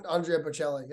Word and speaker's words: Andrea Bocelli: My dad, he gets everybody's name wrong, other than Andrea Bocelli. That's how you pronounce it, right? Is it? – Yeah.